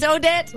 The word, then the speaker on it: Odette: ¿Cómo se pronuncia tu apellido? ¿Odé? No. – No, pero mi apellido es francés, ¿eh? – ¡Ah! Sí, Trisha Odette. – ¡Es Odette!